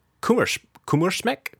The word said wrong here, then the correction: Kummerspeck: K U M M E R S P E C K. Kummerspeck.